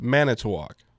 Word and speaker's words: Manitowoc: Is it Manitowoc, or am I saying it, which syllable gets the emphasis? – It's Manitowoc. – Oh, it is Manitowoc. Okay, yes. – I've been saying it wrong all this time.